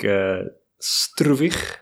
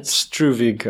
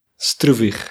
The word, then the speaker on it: Struwig: That would be the way I pronounce it. Struwig, I guess, for English-speaking people. – So it's Struwig for English-speaking people, but it's Struwig. – Struwig. – Struwig. And that's Afrikaans.